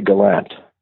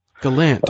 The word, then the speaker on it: Gallant: Around here, people say gallant. – Galant. – Uh, okay. But if you said it in French, Acadian French, it would be galant. And it, it gets various pronunciations, and, and uh, it, it, it's not a, it's not a big deal. We're quite used to it. Some people say will say gallant.